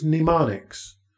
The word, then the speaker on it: mnemonics: Now, in school, and particularly in grad school, we use mnemonics. That's M-N-E-M-O-N-I-C-S, mnemonics. The M is silent.